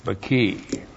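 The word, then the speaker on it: baki (בְּכִי): B-Shava K long I, baki.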